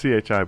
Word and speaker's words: Chi: If you had Christ, which would and it says CHI, but I don't know the proper pronunciation of it. – Chi, which would have been cross.